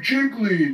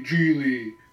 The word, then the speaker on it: Gigli: It's like, oh, Jiggly. It's like, it's actually Gigli.